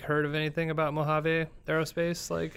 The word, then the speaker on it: Mojave: Mojave.